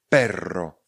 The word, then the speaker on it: perro: Perro je slovo, které se píše P-E-R-R-O. Perro. Perro. Je to rozdíl, protože perro se píše i s jedním r. Vyslovujeme perro. Perro. Ale s dvěma r vyslovujeme perro.